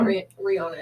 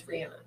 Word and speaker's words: Rihanna: That's how you say it, right? It's not Rihanna, it's Rihanna.